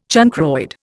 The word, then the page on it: chancroid: Indian